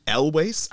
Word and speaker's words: Elwes: Stars Cary Elwes. I think Elwes is how you pronounce that. I'm getting some nods from Marcus. Marcus is like, yep.